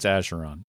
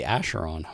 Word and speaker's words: Acheron: it is, Acheron, part one season 11, episode one. I'm pretty sure it's Asheron. oh, really? Asheron. Okay.